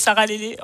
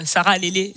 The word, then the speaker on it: Sarah Lélé: Comme il l'a dit, moi je m'appelle Sarah Lélé, enchantée. Pour ceux qui me cherchent sur Instagram, ça s'écrit Sarah, S-A-R-A-H.L-E-L-E. Sarah Lélé, ça s'écrit vraiment comme ukulélé sans le Sarah.